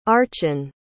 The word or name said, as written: archan